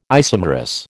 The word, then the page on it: isomerous: American English